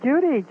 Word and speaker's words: Judy: No, it's Judy, J-U-D-Y. – Oh, all right. Well, there you go. It has A-I-D-A.